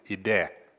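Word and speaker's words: ide (ἴδε): You pronounce it ide, I-D-E in English.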